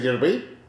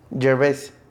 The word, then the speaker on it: Gervais: Es como, ¿cómo se dice en inglés Gervais? – Gervais. – ¿Ya? – Gervais.